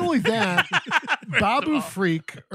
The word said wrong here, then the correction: Babu Frick: or whatever his fucking name. Babu Frick.